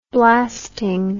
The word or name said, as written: blasting